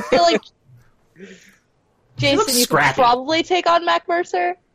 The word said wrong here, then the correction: Matt Mercer: Did I say his name right? – Matt Mercer. – That's the one.